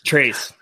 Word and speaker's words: Trace: Metcalf and I had some beef last year about Tracy Jackson Davis. And it wasn't even like beef. It was. – It might help if you pronounce his name correctly. – Trace. Sorry.